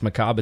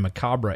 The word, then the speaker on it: macabre: What it is it's pronounced macabre in the U.S. and macabre in the U.K.